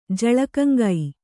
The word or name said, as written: ಜಳಕಂಗೈ